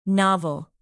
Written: novel

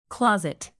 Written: closet

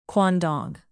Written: quandong

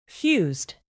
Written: fused

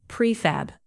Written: prefab

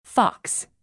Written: fox

